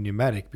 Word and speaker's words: pneumatic: We don't say the P in pneumatic because the P, N is harder to say because it's the beginning of the word. So if the word starts with P, N, you don't pronounce the P, you just go, because that would be pneumatic.